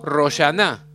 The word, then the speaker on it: Rosh Hashaná: Cada año el pueblo judío celebra Rosh Hashaná, que pronunciamos Roshaná entre los sefaradim, y esto es a partir de la noche del primer día del mes hebreo de Tishrei.